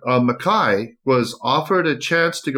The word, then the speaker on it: Mackay: Mackay, yes. – Mackay. – Yeah. Okay. It'll be the Scottish pronunciation.